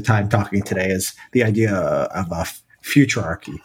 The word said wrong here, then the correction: futarchy: Futurarchy is that is that how you pronounce it? – Futarchy would be a sort of fancy name for decision markets applied to government.